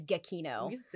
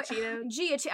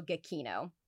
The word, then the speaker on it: Giacchino: It is Giacchino. – What did we say? – I was calling the boy Giacchino. I was butchering this man's last name. It looks like Giacchino. It looks like cappuccino or something.